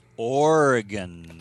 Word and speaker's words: Oregon: Today is a very special day on Photo Focus because he's back by popular demand all the way from Oregon. Hi. – Hey. And you know what? It's Oregon.